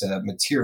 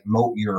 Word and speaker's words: Motyer: And it's Motyer. It's Matthieu is how you say it, but it looks like Motyer or something like that.